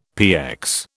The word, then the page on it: PX: British English